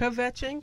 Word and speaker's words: kvetching: Kvetching? – It's not done.